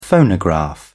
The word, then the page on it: phonograph: British English